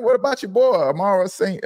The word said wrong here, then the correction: Amon-Ra St. Brown: Amon-Ra St. Brown.